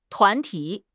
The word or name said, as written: 团体